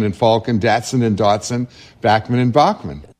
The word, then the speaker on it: Bachman: Let me just say that Randy Bachman himself tells us here that the proper pronunciation is indeed Bachman. – Canadian is Bachman. If I phone somebody in the States to say it's Randy Bachman, they go, who?